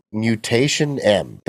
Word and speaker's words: Mutationem: Number five, ANNO Mutationem. The last word is mutation and they add E-M. So Mutationem, I think that's how you pronounce it.